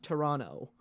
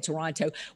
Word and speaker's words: Toronto: Well, people from, you know, Southern Ontario, the correct way to say it is you don't say the second T. It's just Toronto. – Toronto, really? I've always said Toronto.